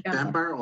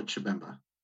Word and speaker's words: Chibemba: So is the language Bemba or Chibemba? – Either or. – Either way. Okay. – Yeah. But people usually say Bemba.